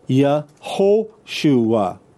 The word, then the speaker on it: Yehoshua: The majority of the time you have Joshua in the Tanakh, it's spelled Yehoshua with an O sound, not with an U sound.